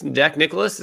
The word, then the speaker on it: Nicklaus: Where I'm going with it is basically, uh, basically Jack Nicklaus, Jack Nicklaus is how you say it, right? – Yeah.